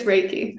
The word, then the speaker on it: Reiki: Is it Reiki or Reiki? – It's Reiki. – Reiki, that's what I thought.